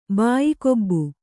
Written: ಬಾಯಿಕೊಬ್ಬು

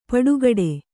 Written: ಪುಟಗೊಡು

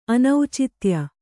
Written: ಅನೌಚಿತ್ಯ

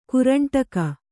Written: ಕರಂಡಕ

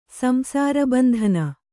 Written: ಸಂಸಾರಬಂಧನ

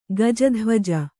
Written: ಗಜಧ್ವಜ

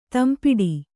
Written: ತಂಪಿಡಿ

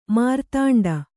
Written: ಮೇರುದಂಡ

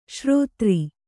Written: ಷೇರುದಾರ

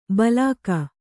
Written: ಭೀಲುಕ

